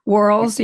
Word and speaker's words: whorls: And they have those whorls. I don't know how to say it. – Whorls, yeah, Whorl. – WHORL, yeah. – Yeah, I don't think you're supposed to say the H, but I do because I don't want people to think that I'm whirling.